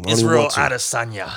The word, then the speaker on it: Israel Adesanya: Israel Adesanya.